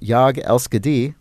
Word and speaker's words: Jag älskar dig: Jag älskar dig, Swedish for I love you. Pronounced along the lines of Jag älskar dig.